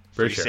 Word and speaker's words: Pitre: Pitre, right? I think so. Is how you pronounce it. – Free sure.